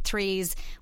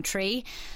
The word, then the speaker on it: threes: It's just my mother's always told me to pronounce my threes with the H, and I always used to get given out to for saying tree.